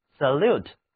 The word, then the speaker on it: salute: 跟 solute 看上去很像的单词是 salute， salute 就是把字母 o 换成了字母 a，在这里字母 a 读音是倒写的元音 a。 Salute，而且啊，重音转移到了后面一个音节上。再跟我读一遍 Salute，它的意思就是我每次在节目开始之前给大家做的那个动作，也就是打招呼、行礼。